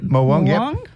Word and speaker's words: morwong: Trevelli, blackfish, tailor, yellowtail, blue mackerel, leather jackets, snapper, morwong, have I said that right? – Yep, morwong.